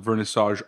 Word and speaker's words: Vernissage: The co-founders of Vernissage Art Advisory.